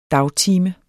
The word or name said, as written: dagtime